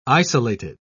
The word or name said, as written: isolated